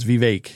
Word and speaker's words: Vivek: So here's my thing, and everybody knows this, but I am a huge Vivek Vivek. Vivek I'm sorry, Vivek. Vivek. Re, no, it's Vivek because it's like cake.